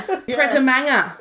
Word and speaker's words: Pret a Manger: Pret a Manger? – Yeah, you're doing great. – Your French accent's better than hers, mate. – What's so great. Yeah. Pret a Manger.